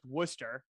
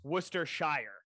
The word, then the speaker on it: Worcestershire: The sauce written down looks like a nightmare to pronounce unless you know that Worcester is pronounced Worcester, and then it's Worcestershire, Worcestershire sauce, but it looks terrible.